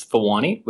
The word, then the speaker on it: Thawani: It's Thawani with a Thawani.